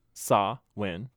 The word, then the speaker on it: Samhain: So Samhain, pronounced Samhain.